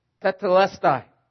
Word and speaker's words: tetelestai: In the original language that the New Testament is written in, that word, "it is finished," means, or is pronounced, tetelestai. Tetelestai. It means to be settled once and for all.